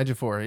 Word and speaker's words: Ejiofor: Ejiofor. – There you go. Yeah. – Is that even close? – Yeah, Ejiofor.